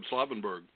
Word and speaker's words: Slavenburg: By the way, what do you think we should say Slavenburg or Slavenburg? What would you I'd pronounce his name Jakob Slavenburg. – Slavenburg. All right.